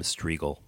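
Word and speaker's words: Strigl: Striegel, my last name is spelled S-T-R-I-G-L. M-S-T-R-I-G-L.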